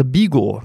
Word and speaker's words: Vigo: Unser Ziel für heute ist Vigo, beziehungsweise Bigo, weil im Spanischen spricht man das V ja wie ein B.